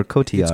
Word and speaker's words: Cotillard: Is it Cotillard or Cotillard? – It's Cotillard. – Okay, I always wondered. She's French, right? – Yeah, you have to... The double L's is pronounced with a Y.